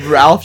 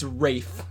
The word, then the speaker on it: Ralph: It's like Ralph just jumps in from the corner. Wait, what's his name? Is it Ralph? – It's Rafe. – Rafe. – It's spelled Ralph, but he goes Rafe.